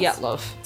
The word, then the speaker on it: Dyatlov: Dyatlov.